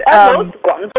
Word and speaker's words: Guangzhou: Did I say that correctly? Guangzhou? And Guangzhou. – Guangzhou, there it is. I missed the U.